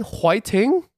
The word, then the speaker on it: 화이팅: Yeah, it's when Korean people can't pronounce fighting because they have a hard time pronouncing certain letters in English. So it's like, fuh, they can't do it. – So they go HWAI-TING? – Yeah, that's the closest thing.